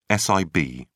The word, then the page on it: sib: British English